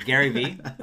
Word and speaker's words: Gary V: I've got this brain fart that's Jerry V, but Gary V.